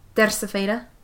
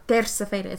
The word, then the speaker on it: Terça-feira: Terça-feira. – Terça-feira? – Terça-feira.